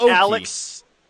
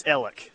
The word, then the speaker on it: Alex: It's not Alex, it's Ellick. So there's a few of those across the state. – It's what now? – There's a town spelled Alex, A-L-E-X. – Okay. – It's pronounced Ellick. – What on earth? – Yeah, I grew up around Ellick, so I know that. I don't know if that's known statewide, that it's Ellick and not Alex, but yeah, that's one of the tricky ones out there.